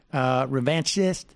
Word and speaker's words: revanchist: Because Fox and Friends is basically, their job is to tell the casual revanchivist, am I saying that right? Revanchist, the casual revanchist, you know, what to think, but needs to be done in such a way that it's like it is spoon-fed and pre-digested.